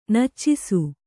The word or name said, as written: ನಚ್ಚಿಸು